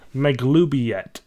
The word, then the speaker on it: Maglubiet: You really gotta get out every fucking syllable. – Yeah. Ma? – Ma? Gloob? – Gloob? E? – Yet. No, yet. – Yet. Yeti? – Maglubiet.